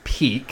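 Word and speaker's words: Pieck: We get Peek walking in, so it is pronounced Peek.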